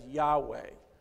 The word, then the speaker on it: Yahweh: And this week, I chose to use the most holy and personal name of God, which we pronounce as Yahweh.